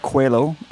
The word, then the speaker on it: Coelho: It was Paulo Coelho, the alchemist. This is... – That was very good Portuguese pronunciation. – Coelho.